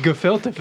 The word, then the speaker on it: gefilte: and it's pronounced gefilte fish. – Gefilte fish. I knew that.